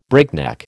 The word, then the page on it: breakneck: American English